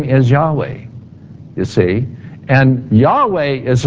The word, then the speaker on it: Yahweh: We don't know how to pronounce it, but it's Yahweh. YHWH, or some would say Jehovah.